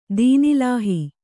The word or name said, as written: ದೀನ್‍ಇಲಾಹಿ